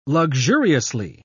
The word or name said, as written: luxuriously